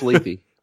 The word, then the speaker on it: Lethe: It's pronounced Lethe.